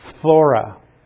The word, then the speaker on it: phthora: Corruption is the Greek word phthora. And that's a tough one to pronounce. It's like P-H-T-H, if you can pronounce that. Phthora.